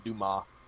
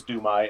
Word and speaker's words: Dumas: And for any nerd out there that's going, it's not pronounced that way. We know it's Dumas. You have to forgive the droid. He hadn't had a memory wipe. He's on. – It is Dumas, isn't it? – Yep.